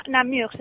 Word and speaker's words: Namur: Dinos tú, aclárame cómo se pronuncia, porque no lo sé. – Namur se dice, pero bueno, sí. Namur decimos todos.